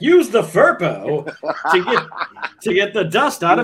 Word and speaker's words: Firpo: I believe you were pronouncing it perfectly, but I don't know what a Firpo is.